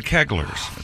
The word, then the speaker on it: Keglers: I think it's the Kunta Keglers. It's not the Kieglers.